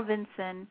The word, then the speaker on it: Vinson: they kept pronouncing the name wrong. Like the USS Carl Vinson's, the Carl Vincent, which I was guilty of saying that a lot, Craig would say. It's not Vincent, it's Vinson with the S-O-N.